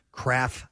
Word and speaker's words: Krapf: Or do you pronounce it Kraft? – No, it's Kraft. – Kraft School Bus, spelled K-R-A-P-F.